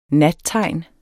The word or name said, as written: nattegn